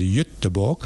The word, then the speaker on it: Göteborg: in Stockholm and Gothenburg, otherwise pronounced in Swedish as Göteborg.